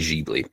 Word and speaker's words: Ghibli: If we want to pronounce it right, it'd be Ghibli. But anyway, where's the fun in pronouncing things right? – Kiki.